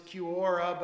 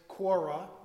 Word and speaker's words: Quora: I've always pronounced it Quora, but I think the official way is Quora, like Flora.